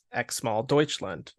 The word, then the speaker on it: X-Mal Deutschland: Yeah, so my underrated pick is a band who, for the longest time, I didn't know how to say their name, but it's really simple. It's X-Mal Deutschland. It is how it looks.